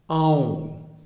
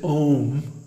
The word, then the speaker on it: Aum: So anyway, this contains some very deep secrets that the pranava, Aum, don't mispronounce it Om, because first of all, it has three letters, A-U-M. A-U-M. And each one gets a full beat. So, it's not Om, it's A-U-M.